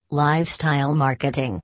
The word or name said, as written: Lifestyle Marketing